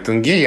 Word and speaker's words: тенге: Ударение в котором ты всегда путаешь, но сейчас ты сказал верно, я уж тудинера проверял. Спасибо, да, я всегда путаю ударение в этом слове, и сколько бы я ни запоминал, что правильно тенге, я через 10 минут об этом забуду и опять буду сомневаться.